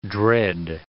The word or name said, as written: dread